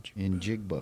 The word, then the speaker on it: Njigba: Pronounce his last name. – Jackson Smith and Jigba. – Njigba. – Okay. See, looking down here. – Njigba. Okay. Yeah, I see it now.